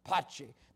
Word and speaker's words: Pace: Those that have been to Moldova know when you meet someone there, they greet you with the word "Pace." Pace. They say it like they're almost spitting on you.